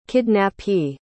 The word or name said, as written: kidnappee